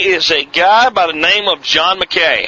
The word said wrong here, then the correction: Mackey: Mackey, I think is probably the right way to say his name. Who is John McKay or John Mackey? Mackey. M-A-C-K-E-Y. That's Mackey.